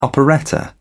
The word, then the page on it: operetta: British English